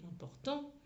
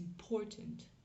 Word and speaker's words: important: un anglophone, va forcément dire it's important. Pour, parce que l'accent tonique est sur cette syllabe-là, sur la syllabe du milieu.